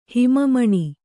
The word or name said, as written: ಹಿಮಮಣಿ